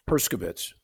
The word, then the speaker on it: Herskovitz: Herskovitz. No H. Herskovitz.